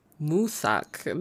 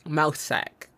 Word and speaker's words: Mousesack: Moosak, not mouse. Like, that's what it looks like, Mousesack, but that's not how you say it.